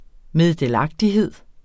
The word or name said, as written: meddelagtighed